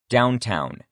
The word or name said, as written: downtown